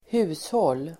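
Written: hushåll

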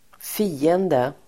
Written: fiende

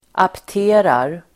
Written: apterar

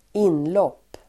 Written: inlopp

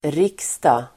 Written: riksdag

